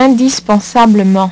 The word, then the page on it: indispens: French